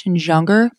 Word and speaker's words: Junger: It is by Sebastian Junger. It's a soft J.